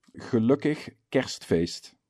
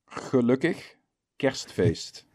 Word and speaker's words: Gelukkig Kerstfeest: Gelukkig Kerstfeest. – Wow. Say that again. – Gelukkig Kerstfeest.